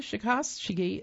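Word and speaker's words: Czechowski: Lisa Shikoski of Fort Wayne, Indiana, that's spelled C-Z-E-C-H, like Czechoslovakia, O-W-S-K-I,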